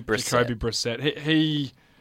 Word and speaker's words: Brissett: I think, is it Brissett? That's how you say it, I think. – Brissett. Jacoby Brissett.